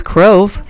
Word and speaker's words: Crove: We're going to be hearing The Crove or The Grove. I'm just trying to see this. It says Crove. Okay. The Crove. So it rhymes with Grove, but it starts with a C.